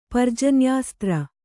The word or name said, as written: ಪರ್ಜನ್ಯಾಸ್ತ್ರ